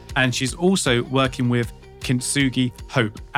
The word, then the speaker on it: Kintsugi Hope: And I hope I said that right, because I said it to her earlier. Sometimes I get that word wrong and I really shouldn't. Julie Wilson, am I correct? – You are correct.